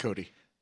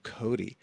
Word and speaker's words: Cote: He's the theater editor and critic for Time Out New York, and he and his fellow Time Out colleague, David Cote, am I saying that right? – Cody. – Oh, Cody. – Buffalo Bill Cody.